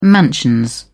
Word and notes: mansions: British English

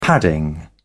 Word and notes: padding: British English

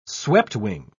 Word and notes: sweptwing: British English

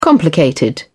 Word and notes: complicated: British English

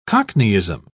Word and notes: cockneyism: British English

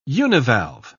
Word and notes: univalve: British English

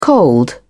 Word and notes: cold: British English

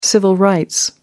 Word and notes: civil rights: American English

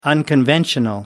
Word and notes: unconventional: American English

